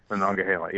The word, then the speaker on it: Monongahela: Monongahela, yes.